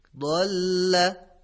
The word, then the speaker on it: ضل: همونطور که ملاحظه میکنید کلمه ضل با تلفظ زاد به معنای گمراه شد هست، ولی اگر همین کلمه رو با حرف زا تلفظ کنیم، یعنی بگیم زل، به معنای لغزید هست. میبینید که چقدر معنا متفاوت شده.